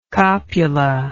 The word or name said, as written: copula